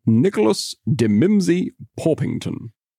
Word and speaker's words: Nicholas de Mimsey Porpington: Nicholas de Mimsey Porpington. Go on, give it a go in British English. – Nicholas de Mimsey Porpington.